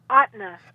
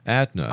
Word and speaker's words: Ahtna: Ahtna. – Ahtna. – Ahtna.